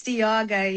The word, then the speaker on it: Dijaga: And so we're having this little bit of a, and only to find that you are like Jaga as in Jagger. – It's Dijaga, yeah, no.